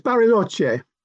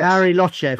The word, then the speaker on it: Bariloche: You're a freelance writer and you're based in Manchester and Bariloche, and I hope I said that right, in Argentina. – It's Bariloche. Yes. – Bariloche, of course it is.